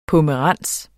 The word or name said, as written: pomerans